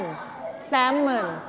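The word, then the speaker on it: salmon: แซลมอนไม่ออกเสียง L นะแซลม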